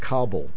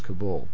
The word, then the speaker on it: Kabul: By the way, in case anybody's interested, the mainstream media seems to be pronouncing it Kabul, but when I lived there, it was Kabul.